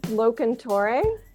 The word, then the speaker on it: Locantore: It's Locantore. – Locantore. Okay. I thought so. I just wanted to make sure. – It's Italian, so just channel your inner Italian.